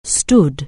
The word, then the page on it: stood: anglais